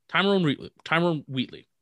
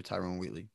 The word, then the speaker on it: Tyrone Wheatley: Probably saying his name wrong. – No, Tyrone Wheatley. That's his name. – Tyrone Wheatley.